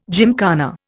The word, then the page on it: gymkhana: Indian